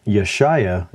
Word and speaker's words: Yeshaya: That Yeshaya, it sounds like there's an I in there, a long I, but it's just an A. And when you form the word, write out the letters in the proper way, an A and a Y and another A just sound like I. There's no way to remove that long I sound.